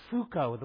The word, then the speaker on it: Foucault: Probably the most famous postmodernist is Michel, it's Foucault, the way you say his name. It's pronounced, it looks real different.